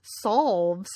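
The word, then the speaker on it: salves: They're gentle and emollient-packed solves. So many silent L's here.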